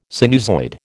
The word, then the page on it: sinusoid: American English